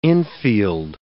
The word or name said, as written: infield